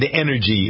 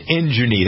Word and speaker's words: energy: Money, M-O-N-E-Y, and injury. That's how I used to say it when I didn't know how to say it. Injury. – Oh, you didn't know how to say energy?